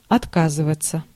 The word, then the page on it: renoncer: France; [ʁə.nɔ̃.se]